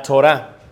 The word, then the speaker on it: Torah: Número uno, la ley, que la pronuncian ellos como la Torah.